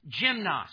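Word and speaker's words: gymnos (γυμνός): The Greek word here, open, I'm going to pronounce it because you're going to hear the English word in it, gymnos, gymnasium, gymnast.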